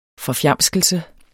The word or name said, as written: forfjamskelse